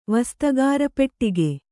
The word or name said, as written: ವಸ್ತಗಾರಪೆಟ್ಟಿಗೆ